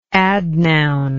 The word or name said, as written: adnoun